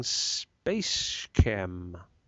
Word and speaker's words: SpaceChem: Space Chem, I guess, but it stands for space chemicals, so I'm not quite convinced by your argument.